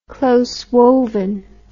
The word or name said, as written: close-woven